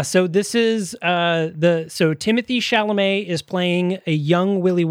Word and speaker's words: Chalamet: Timothée Charlemagne? What's his name? – Chalamet? – Timothée Chalamet.